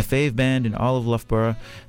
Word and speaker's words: Loughborough: And yeah, we just heard a few songs from one of Burnaby's sister cities, who I just learned the proper pronunciation of. It's Loughborough, right in the middle of England.